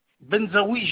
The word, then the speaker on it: Benzaoui: Benzaoui Gérard. – Ça s'écrit ? – B-E-N-Z-A-O-U-I. Gérard. – A-O-I.